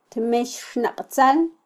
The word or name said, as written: tmexxnaqtzal